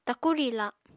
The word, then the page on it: taku riila: Apatani